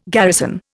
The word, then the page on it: garrison: Indian